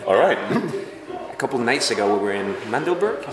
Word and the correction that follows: Magdeburg: Is that how you say it? – Magdeburg. – Magdeburg.